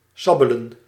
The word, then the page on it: suck: /sʌk/